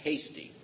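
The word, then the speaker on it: Heastie: and it's pronounced Hasty, I was told.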